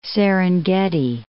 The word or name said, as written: Serengeti Plain